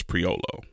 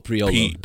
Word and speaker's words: Priolo: But I think the way to spell it is, say it is Priolo. – Oh, Priolo. – P-R-I-O-L-O. Yeah.